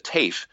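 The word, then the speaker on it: Taaffe: And so the name Taff, T-A-A-F-F-E, I just said it the way we say it. It's where I come from, near Galway, and but the student that we have here pronounces it TAFE.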